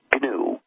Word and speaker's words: GNU: Well, actually, no. It's pronounced GNU. – GNU, I know.